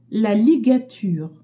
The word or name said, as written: ligature